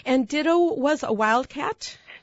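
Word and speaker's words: Ditteaux: Did you notice how it's spelled, too? – Yes, I love it. It's D-I-T-T-E-A-U-X. – Right. – With a French twist, of course, you being in Louisiana.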